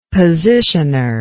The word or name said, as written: positioner